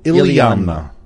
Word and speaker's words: Iliamna: The little town is called Iliamna.